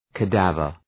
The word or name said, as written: cadaver